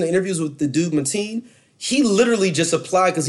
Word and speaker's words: Mateen: It's Mateen.